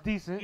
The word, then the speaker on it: Dyson: Spelled Dyson, but pronounced Decent.